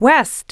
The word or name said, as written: west